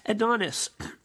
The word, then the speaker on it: Adonais: That's spelled A-D-O-N-A-I-S, as if it were to be spelled Adonais. Adonis,